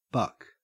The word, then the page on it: buck: Australian English